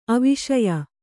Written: ಆವಶ್ಯ

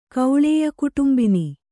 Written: ಕೌಳೇಯಕುಟುಂಬಿನಿ

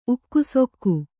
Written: ಉಕ್ಕುಸೊಕ್ಕು

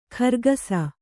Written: ಖರ್ಗಸ

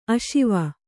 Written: ಆಶ್ವ

